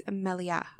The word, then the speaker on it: Melia: The accent is on the A, so therefore it's Melia.